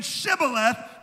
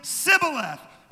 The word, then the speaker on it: Shibboleth: So when they thought they were saying Shibboleth, they really were saying Sibboleth.